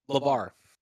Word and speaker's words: LaVar: Pat, if you could say your last name, LaVar. – LaVar Ball. Like LaVar Ball.